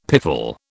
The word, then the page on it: piffle: British English